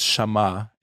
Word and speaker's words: Shammah: His name is Shammah. He's mentioned maybe just once or twice. He was one of the bodyguards of King David's guard. That's the right way to say it.